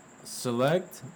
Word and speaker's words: Sillect: Uh, uh, select, but it's spelled S I L L E C T Avenue.